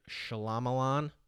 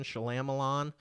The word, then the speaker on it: Shyamalan: Night Shyamalan. I'm deliberately mispronouncing that. Shyamalan.